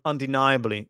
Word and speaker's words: undeniably: A good word, undeniably. By the way, the stress, where is the stress on that word? Undeniably, nigh. The nigh syllable is stressed.